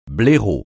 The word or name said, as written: blaireau